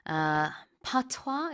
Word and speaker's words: patois: Now, what has emerged instead is an idiosyncratic patois.